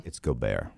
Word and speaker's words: Gobert: Right? Gobert. He's French. – Okay, so I was reading it and I was like, how do you pronounce it? Because I had Goddard stuck in my head football. – It's Gobert.